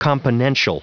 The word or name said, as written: componential